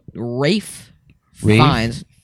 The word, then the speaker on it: Ralph Fiennes: What Rafe Fiennes. Although yeah. It's spelled Ralph Fiennes, whatever.